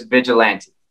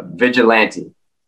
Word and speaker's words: Vigilante: So, anyways, we're going to listen to, and if I may try to pronounce it correctly, Kimball, Vigilant. – Oh my God. – Vigilant. – I'm sorry. Oh, yes. The song name is Vigilante.